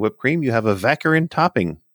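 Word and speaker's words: vacherin: Our next word is vaccarin. Which is rhymes with saccharin, and it is a sweet mixture of meringue and whipped cream.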